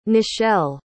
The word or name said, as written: nichelle